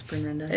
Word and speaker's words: Bryn Renda: Most people just mush it together like it's one name. – Yeah? – You know? It's Bryn Renda.